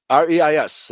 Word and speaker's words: Reis: Reis, R E I S. That's a German name pronounced Reis.